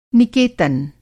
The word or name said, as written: निकेतन